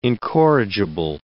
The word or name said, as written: incorrigible